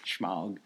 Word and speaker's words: Smaug: Smaug.